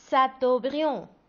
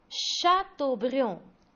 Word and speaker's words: Chateaubriand: Et on ne prononce pas Chateaubriand. On prononce Chateaubriand. – Chateaubriand.